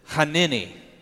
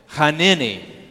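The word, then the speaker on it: hanini (הִנֵּנִי): You pronounce it hanini. Say that with me, hanini.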